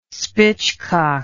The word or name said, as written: spitchcock